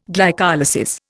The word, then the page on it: glycolysis: Indian